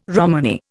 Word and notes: Romany: Indian

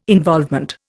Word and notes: involvement: Indian

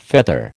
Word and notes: feather: American English

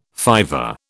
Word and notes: fiver: British English

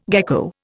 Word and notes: gecko: Indian

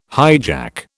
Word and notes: hijack: British English

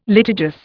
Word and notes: litigious: Indian

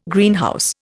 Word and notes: Greenhouse: Indian

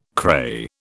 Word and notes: cray: British English

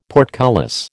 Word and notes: portcullis: American English

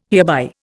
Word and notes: hereby: Indian

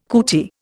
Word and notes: cootie: Indian

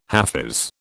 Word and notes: hafiz: British English

